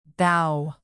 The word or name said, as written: bough